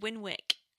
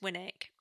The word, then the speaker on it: Winwick: Because it's funny because everyone calls it Winwick And it's actually pronounced Winnick, like without the W.